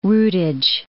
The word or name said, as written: rootage